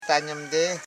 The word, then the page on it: tanyim: ta.nyim